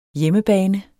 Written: HJEMMEBANE

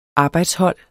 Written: arbejdshold

